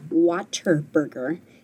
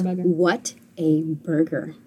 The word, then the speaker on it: Whataburger: And so the whole, like, going to, well, you guys, McDonald's, in Texas, it's Whataburger. – Oh, yeah. Oh, yep. Yep. – I've heard of it. Someday. Oh, my gosh. I've never been to one. – No, it is not Whataburger. It's Whataburger.